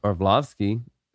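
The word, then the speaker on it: Arlovski: Orlovsky. I said that right. – Yeah.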